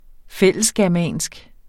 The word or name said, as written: fællesgermansk